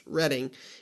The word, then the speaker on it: Reading: Lou Janeiro, you've got to choose Reading, pronounced Reading, FC as your championship team to support.